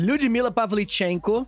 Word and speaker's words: Lyudmila Pavlichenko: Lyudmila Pavlichenko.